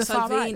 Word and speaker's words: Salvini: Do you think it becomes of global significance what's happening in Italy because of, I can't pronounce his name, Silv- is it Silvani? Oh, Salvini, right Salvini. Salvini.